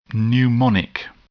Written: pneumonic

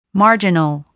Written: marginal

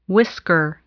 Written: whisker

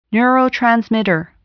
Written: neurotransmitter

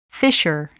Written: fissure

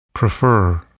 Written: prefer

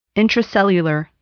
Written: intracellular

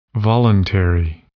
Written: voluntary

